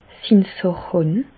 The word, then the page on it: sin sàch hàn: Bas-Rhin; Reichshoffen